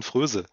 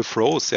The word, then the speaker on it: Froese: Da spielt nämlich jemand, der heißt Kienz, und der Nachname wird dann geschrieben F-R-O-E-S-E. Ja, würde man deutsch aussprechen, Fröse.